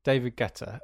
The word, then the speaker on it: Guetta: David Guetta. As someone who used to work in commercial radio, I was told on several occasions that I was pronouncing his name wrong by my bosses. So, David Guetta.